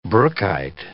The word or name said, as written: brookite